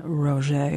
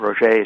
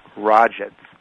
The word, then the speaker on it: Roget: She said it's not Rogets.